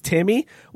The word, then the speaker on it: Timme: It's Timmy. Wait, it's really Timmy? Spelled T-I-M-M-E.